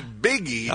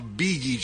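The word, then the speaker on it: Bigi: Not Biggie. – Oh. – Beegee. – Beegee. – Beegee. – That's, okay. – Beegee is a German name that means idealistic, sensitive, and inspirational. Pronounced Beegee.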